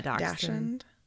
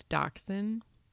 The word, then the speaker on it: dachshund: Yeah, dachshund. It's dachshund, but it's spelled like Dachshund.